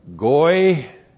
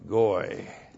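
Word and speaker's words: goy (גּוֹי): And then we have goy. G, long O, Y, goy.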